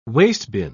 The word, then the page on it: wastebin: British English